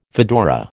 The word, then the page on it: fedora: American English